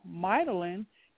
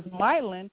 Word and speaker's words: myelin: If the liver does not produce processed oil, myelin that covers the axons would not be produced because myelin is made with lipids. Am I pronouncing that right? Myelin is made with lipids. – Yes, with oils.